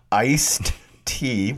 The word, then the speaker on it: iced tea: Okay, the reason that I fill that glass full of ice to the top and then pour in the iced, and notice I said it properly, iced tea. What has happened to the tea? It has been iced.